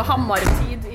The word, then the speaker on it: hammertid: So Hammertid is kind of Hammer Time in Norwegian.